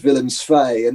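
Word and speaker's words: Willem II: And I can't speak Dutch, but I can pronounce Dutch, like VVV Venlo is Vey Vey Vey, and Willems 2 is Willems Fay, and you know all those team names and you kind of get the pronunciations, and gradually it becomes second nature.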